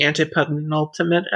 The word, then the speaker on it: antepenultimate: Yeah, I remember the first time that I watched Ozymandias, I think it's the antepenultimate episode, if I'm pronouncing that correctly. – Yes.